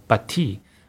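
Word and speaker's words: Pathé: 呃，这个公司我后来看了一些 YouTube 的影像啊，就是标准读音应该应该是 Bati，然后 Bati 怎么变成百代？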